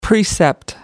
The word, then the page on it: precept: American English